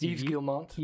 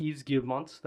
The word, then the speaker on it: Yves Guillemot: I can't pronounce the French CEO's name of Ubisoft. I think it's Yves Guillemot. – Yves Guillemot. That's beautiful pronunciation.